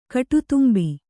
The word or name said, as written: ಕಾಡತುಂಬೆ